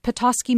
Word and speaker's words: Petoskey: Let's go to Rachel in Petoskey, Michigan. Am I saying that right? – You are right. You are correct.